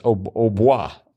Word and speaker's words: au bois: And that was, so I guess it should be pronounced au bois. Yeah, it would be if it was actually in French.